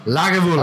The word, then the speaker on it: Lagavulin: Lagavulin.